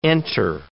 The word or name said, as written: enter